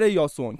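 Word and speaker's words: یاسون (Ἰάσων): مخصوصا سر یاسون که کل دنیا به اسم جیسون میشناسنش ولی تلفظ یونانیش یاسونه.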